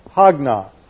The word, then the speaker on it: hagnos: And this is from the Greek word hagnos. Looks like this, H-A-G-N-O-S.